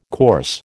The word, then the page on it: course: American English